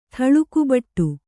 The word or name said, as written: ಥಳುಕುಬಟ್ಟು